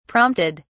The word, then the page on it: prompted: British English